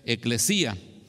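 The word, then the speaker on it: eclesía (ἐκκλησία): Hay 117 referencias eh, en el texto griego a la palabra iglesia, que en griego se pronuncia eclesía.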